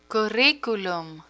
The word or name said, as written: curriculum